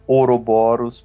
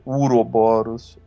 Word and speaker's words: Ouroboros: Que tem várias pronúncias, né? Pode ser Ouroboros, pode ser Uroboros, Ouroboros.